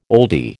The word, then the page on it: oldie: American English